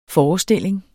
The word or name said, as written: forestilling